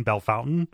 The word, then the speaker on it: Bellefontaine: We pronounced Bellefontaine Bellefountain, so well, yeah.